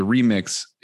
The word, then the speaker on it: ReMIX: And I should say the remix is formatted capital R, lowercase e, capital M I X. There's emphasis on the mix here.